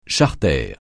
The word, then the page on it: charter: français